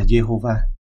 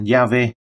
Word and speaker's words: Yahweh: Nếu các nguyên âm của từ Adonai được đặt thay thế cho các phụ âm của Yahweh thì chúng ta sẽ có được cái cách phát âm là Jehovah. Tất cả điều này mãi về sau mới có vào thời của Kinh Thánh, tên này được phát âm là Yahweh hoặc là Yav.